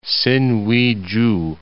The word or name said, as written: Sinuiju